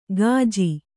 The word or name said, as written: ಕಾಜಿ